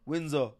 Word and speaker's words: Windsor: The S is basically a Windsor. Z. Windsor.